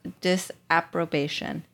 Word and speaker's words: disapprobation: disapprobation. Did I say that right? – Sounds good. – Yeah. – Approbation. – Sounded great to me.